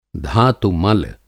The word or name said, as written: धातु-मल